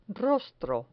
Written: rostro

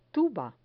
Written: tuba

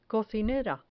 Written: cocinera

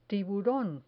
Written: tiburon

